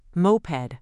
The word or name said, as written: moped